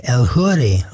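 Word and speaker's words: Eljuri: After that, a nice piece of Latin music, Brisa del Mar, the Montoyo mix by El Huri on the Manaville record label. Her name is spelled El Jury for us English speakers.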